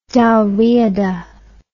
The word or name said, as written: Dalriada